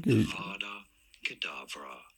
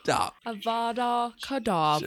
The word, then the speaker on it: Avada Kedavra: I'm like Avada Kedavra. – Oh. – Yeah, you say Avada Kedavra.